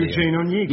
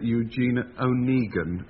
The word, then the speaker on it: Eugene Onegin: Eugene Onegin. – Yes, the other mark. Yes. – Before I came on this program, I always pronounced that Eugene Onegin, as though he was Irish.